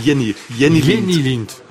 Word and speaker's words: Jenny Lind: D'ailleurs, on ne prononce pas Jenny Lind comme je le croyais. Comment vous dites? – Jenny Lind.